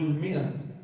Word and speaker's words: مئة: دائما الألف لهذه الكلمات لا تقرأ، لا تلفظ، إنما كانت تكتب ليفرق بها وبين الفئة. كانوا العرب سابقا لا يبقطون، فكانوا كلمة فئة بس نقرأ من أول ماء من أول مئة، مع أنه في ألف، في ألف، ولكنها تكتب ولا تلفظ.